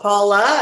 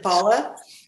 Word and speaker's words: Paula: Almost. Paula, as in Paula, you know, and then Tycho.